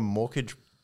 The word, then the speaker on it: mortgage: It was financed by a former mortgage banker. Did I say that right? Mortgage. – No wonder you don't. – Don't pronounce the T.